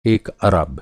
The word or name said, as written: अरब